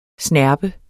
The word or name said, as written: snerpe